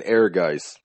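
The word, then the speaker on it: Ehrgeiz: We're saying Airgeist, like you would think, you know, A-I-R, you know, something like that. No, it's E-H-R-G-I-E-Z. That, why?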